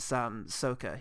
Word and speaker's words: Sokka: What's great about him is he's such a complex character as Sokka.